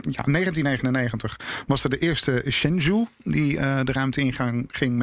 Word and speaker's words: Shenzhou: Ja, en ik heb het het eigenlijk spreek je het uit als Shenzhou.